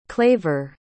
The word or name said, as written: claver